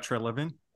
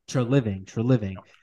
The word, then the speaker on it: Treliving: And I'm going to even throw, how do you say, Treveling? Kirby? – Yeah, Brad Treliving. – Treliving.